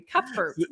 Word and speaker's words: Cuthbert: Brian, you got my unmarried name correct. That's a hard one. Okay. Some people really butcher that, and you did it perfectly. Cuthbert, awesome job.